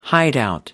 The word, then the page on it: hideout: American English